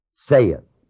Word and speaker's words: saith: Jesus saith in verse 34, and by the way, the word S-A-I-T-H is not saith. How do you spell saith? S-A-Y. That's right. This is not S-A-Y. It is S-A-I, right? Well, look at it. What it is. It's pronounced the same as if it was spelled S-E-T-H.